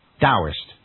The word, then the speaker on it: Taoist: It is actually written Taoist, T as in Tommy, A-O-I-S-T. So it's written Taoist and pronounced with a D, Taoist.